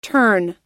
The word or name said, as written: terne